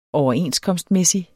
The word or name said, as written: overenskomstmæssig